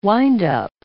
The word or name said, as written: windup